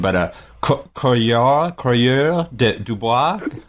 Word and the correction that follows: coureur de bois: Coureur de bois. – But I kept reading it as courier, because that's just me being an American who doesn't speak French.